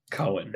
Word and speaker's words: Cowen: It's spelled C-O-W-E-N, so not quite. – Okay. Cohen.